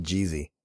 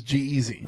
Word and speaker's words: G-Eazy: I think they pronounce it Jeezy. – No, it's not Jeezy. – No, really? – It actually is G-Eazy.